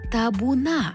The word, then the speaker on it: табуна: але табуна, бо наголос падає на останній склад.